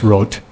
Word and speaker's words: Roth: The great French organist Daniel Rote, it's R-O-T-H, looks like Roth, but pronounced Rote.